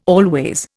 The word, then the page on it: always: Indian